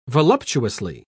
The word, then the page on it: voluptuously: British English